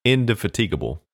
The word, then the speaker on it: indefatigable: Now, the way that Tony pronounced it was indefatigable. So putting the emphasis on tig. – Yes. – Indefatigable. – Well, it goes from the E to the ig sound.